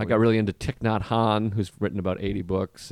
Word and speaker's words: Thich Nhat Hanh: Thich Nhat Hanh, yeah.